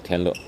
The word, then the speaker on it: 天乐: 李天乐啊，这个官网的这个英文拼写是多音字啊，这快乐的乐，他写的是天乐，实际上是叫天乐。